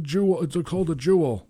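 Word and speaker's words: Juul: It's called a jewel.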